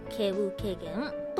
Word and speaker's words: 希有希見: それは、望む、き、ある、そしてまた望む、見ると書いて、経由軽減と読むみたいです。